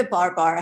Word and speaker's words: Baar Baar: So Bar Bar is not spelled B-A-R-B-A-R. It's spelled as B-A-A-R-B-A-A-R. And in Indian, it means again and again. So the whole tagline is, come to Bar Bar, Bar Bar.